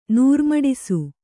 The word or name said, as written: ನೂರ್ಮಡಿಸು